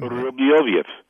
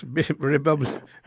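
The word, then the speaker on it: Rybolovlev: But preceding that, there was a meeting in the Hamptons, which included Dmitry Rybolovkov. How do you pronounce his name? – Rybolovkov. – Yes, Rybolovkov.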